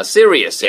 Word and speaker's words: serious: Serious. – Yeah. – S-E-R-I-O-U-S. Serious 해도 되고요. Serious 해도 돼요. 두 가지 발음입니다.